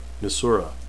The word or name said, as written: nisura